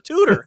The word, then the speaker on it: Tudor: But again, that's one of those names, too, that people, you get calls from, is Mr. Tudrow there? Mr. Tudlington? I'm like, really? That's like four syllables. How'd you get four syllables out of Tudor?